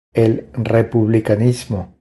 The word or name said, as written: El republicanismo